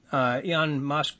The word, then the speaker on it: Elon Musk: I think that's something that, Elon Musk, the fellow with Tesla.